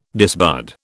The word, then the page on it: disbud: British English